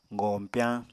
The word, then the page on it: goñpyañ: Apatani